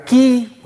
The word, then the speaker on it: chi: De hecho, se llama quiaísmo porque en la palabra griega, la, la, o la ki o la chi, como su profesor le enseñó, yo lo pronuncio,